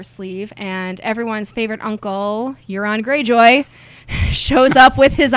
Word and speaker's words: Euron: Because it rhymes, Euron, moron.